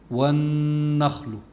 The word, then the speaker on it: والنخل: فعند تلفظ بالنون الواحد بدي يقولها هيك: والنخل، والنخل. الغنة اللي هي مصاحبة للنون تخرج من الخيشوم اللي هو منتهى الأنف وتستمر إلى حركتين: والنخل ذات الأكمام.